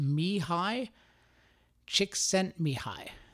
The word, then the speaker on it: Mihai Csikszentmihalyi: But I've just learned from YouTube it's pronounced Mihai Csikszentmihalyi. Mihai Csikszentmihalyi, which that sounds not so bad, but the spelling is just, it's got like L's and Y's, and yet they add up to I.